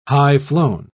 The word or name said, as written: high-flown